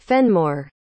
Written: fenmore

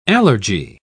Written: allergy

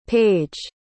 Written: paige

